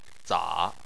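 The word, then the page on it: za: tone 3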